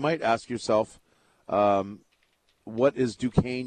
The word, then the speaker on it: Duquesne: Number one, it's not pronounced Duquesne, it's Duquesne.